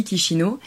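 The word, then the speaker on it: Chișinău: Nous on a vécu dans la capitale donc qui s'appelle Kishino, euh, qui s'écrit Shishino mais qui se dit Kishino.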